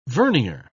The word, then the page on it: vernier: British English